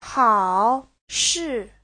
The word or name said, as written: hao3shi4